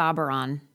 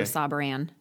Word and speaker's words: Sauberan: How do people normally pronounce your name? – They say Sauberan. – Sauberan. – Instead of Sauberan.